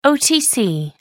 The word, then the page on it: otc: American English